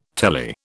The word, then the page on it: telly: British English